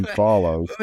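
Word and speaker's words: Fallows: That'll give you like the technical chief for Aston Martin's last name sort of rhymes with swallows. It's Dan Follows.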